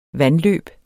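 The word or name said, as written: VANDLØB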